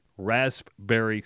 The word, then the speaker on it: raspberry: Today, my wife went with the homemade raspberry cinnamon rolls. – I don't know if you need to pronounce the P.